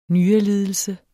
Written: nyrelidelse

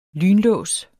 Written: lynlås

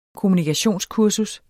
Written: kommunikationskurser

